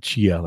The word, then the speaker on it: Chia: You know, Ken and I have been working on this Chia, however you want to pronounce it, C-H-I-A. I want to call it Chia, like Chia, right? – Yeah.